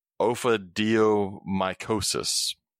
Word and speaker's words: ophidiomycosis: I had this before I got on the podcast, so I'm going to pronounce it now. The status of ophidiomycosis. There you go.